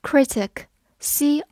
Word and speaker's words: critic: 名词的重音一般放在前面，而动词的重音一般放在后面。这是一个发音上面的小规律，希望大家可以记住哦。 critic，c r i t i c，critic，c r i 发 cri，t i c tic，critic，critic，它是一个名词，表示批评家或者评论家。